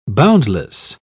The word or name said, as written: boundless